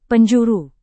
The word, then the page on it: penjuru: Indonesian